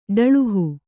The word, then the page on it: ಡಳುಹು: kannada